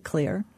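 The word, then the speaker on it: Xlear: There are daytime sprays, like it looks like X-Lear. The Scandinavians pronounce it clear.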